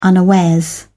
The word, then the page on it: unawares: British English